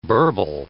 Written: burble

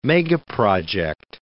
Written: megaproject

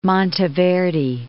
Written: Monteverde

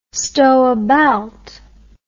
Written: storebaelt

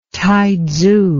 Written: Taitsu

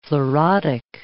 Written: fluorotic

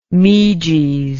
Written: meges

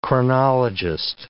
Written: chronologist

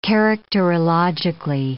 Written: characterologically

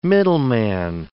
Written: middleman

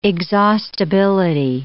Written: exhaustibility

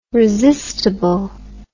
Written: resistable

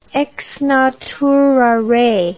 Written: ex natura rei

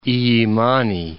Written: Illimani

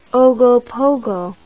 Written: ogopogo

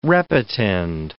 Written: repetend

